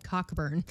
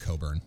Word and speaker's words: Cockburn: It's like the towns in England and Bermuda and Australia that are called Cockburn. – Yep. – I'm like, oh, that's too bad. – Should get that checked out. – Yeah. Ouch. – Never mind that it's actually pronounced Coburn.